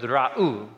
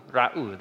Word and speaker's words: ra'u: So you pronounce that word ra'u, ra'u, they see.